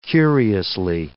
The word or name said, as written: curiously